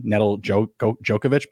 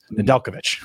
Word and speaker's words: Nedeljkovic: I always want to pronounce out his name, how it's written, Nedeljkovic, but it's Nedeljkovic.